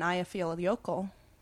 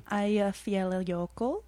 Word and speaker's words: Eyjafjallajökull: And then Eyjafjallajökull, the Icelandic volcano, erupted. – That's a good pronunciation. Can you say it for us one more time? – Eyjafjallajökull.